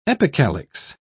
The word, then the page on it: epicalyx: British English